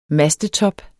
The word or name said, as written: mastetop